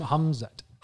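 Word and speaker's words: Khamzat: say deep hum, Humzat. Humzat. Humzat. Humzat. So the, the, K is silent and it's yeah, not the has- K is silent.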